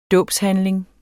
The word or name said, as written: dåbshandling